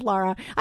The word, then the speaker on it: Lara: I struggle with the pronunciation of Lara. I have a friend whose name is Lara, but not Laura.